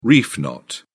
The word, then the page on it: reef knot: British English